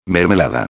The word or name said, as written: mermelada